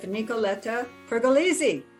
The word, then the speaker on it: Nicoletta Pergolizzi: Nicoletta Pergolizzi.